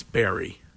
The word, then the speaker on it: Barre: I think it's Barre. – Oh. – Yeah, that's okay. – It's spelled like Pure Barre.